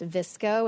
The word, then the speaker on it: VSCO: So VSCO, which is spelled VSCO. It's pronounced VSCO. And yes, I looked it up because I'm like, I did not know how to pronounce it.